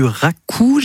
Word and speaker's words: raku: de la terre sigillée et du raku, j'imagine qu'on prononce comme ça. – Oui.